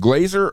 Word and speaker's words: Glazer: How do I say your last name? Glazer or? – Glazer. – Just like Jay Glazer. – You got it.